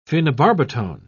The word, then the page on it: phenobarbitone: British English